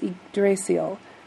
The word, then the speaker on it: Yggdrasil: And it's difficult to pronounce, but I believe it's Yggdrasil.